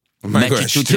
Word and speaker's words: Massachusetts: Massachusetts.